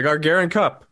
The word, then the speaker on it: Gagarin Cup: The Gagarin Cup.